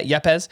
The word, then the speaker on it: Yepez: I should have looked up how to say his name. Yepez. – That's correct. – Yeah, Yepez.